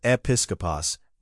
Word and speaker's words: episkopos: 3.1.3, the office logic, e, the requirements for the office of episkopos, pronounced episkopos, overseer elder, utilize male-specific syntax, 1 Timothy 3:1-7, Titus 1:5-9.